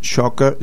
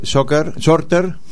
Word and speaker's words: Shorter: James Shocker. Shorter.